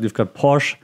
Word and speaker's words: Porsche: Mercedes, then you've got Porsche actually, that's how you pronounce it.